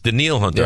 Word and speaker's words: Danielle Hunter: I don't know why it's not Danielle Hunter. Because that's it's Daniil. – It's Daniil Hunter. Yeah. I know that, but it's spelled Danielle.